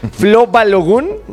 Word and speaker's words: Flo Balogun: Con el tema de Flo Balogun.